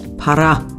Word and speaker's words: para: Para. Para eigentlich. Dann habe ich es auch noch falsch ausgesprochen.